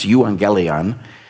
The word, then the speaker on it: euangelion: But what if the gospel, what if the Greek word that's translated gospel in our scriptures is pronounced euangelion? It's where we get our word evangelism, and a direct translation of it really simply just means good news.